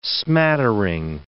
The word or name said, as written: smattering